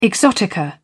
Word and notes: exotica: British English

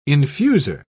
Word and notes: infuser: British English

